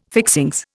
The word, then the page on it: fixings: Indian